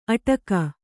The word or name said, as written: ಅಟಕ